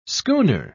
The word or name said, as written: schooner